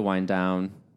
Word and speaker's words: wind down: Unfortunately, it's time to wind down. Did I say that right? Wind down. – Yeah, you said it right.